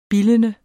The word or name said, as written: bildende